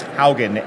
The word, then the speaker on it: Haugen: My last name is Haugen, H-A-U-G-E-N.